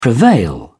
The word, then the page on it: prevail: British English